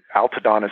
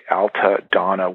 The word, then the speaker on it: Altadonna: Altadonna is spelled Altadonna, one word, Altadonna.